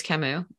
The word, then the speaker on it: Camus: The company's name is Camus. We're named for the French philosopher.